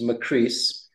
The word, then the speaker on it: McCreese: Well, the first thing that I say is that, you know, my name's easy to remember because it rhymes with Greece. So my last name is McCreese.